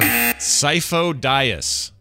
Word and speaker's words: Sifo-Dyas: Sifo-Dyas.